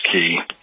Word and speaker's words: Caye: Transportation on the Key, they're spelled C-A-Y-E, it's pronounced Key.